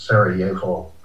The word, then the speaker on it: Sarajevo: So if you're in Eastern Europe, it's a Y sound. So it's Sarajevo.